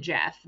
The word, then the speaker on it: Geoff: If you can spell Geoff and pronounce it Jeff, then, you know.